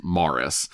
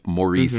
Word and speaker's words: Maurice: Now, the Reverend Brock, the lead character, was played by Morris Evans, and his name is spelled Maurice, but everyone pronounces his name, and he says Morris. So yes. we will pronounce his name correctly and say Morris Evans, not Maurice Evans.